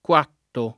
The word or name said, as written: Cuatto